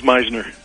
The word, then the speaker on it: Misner: I'm here today with Dr. Ivan Misner, and I hope I pronounced that right. Ivan, did I catch that right? Ah, close enough. It's Meisner. Meisner.